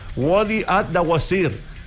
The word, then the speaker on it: Wadi ad-Dawasir: Uniendo las localidades de Bishá, y como bien me dijiste tú, se pronuncia Wadi Ad-Dawasir.